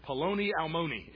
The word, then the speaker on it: paloni almoni: That word for friend is paloni almoni. It rhymes. Hey, yeah, it sounds kind of funny, right?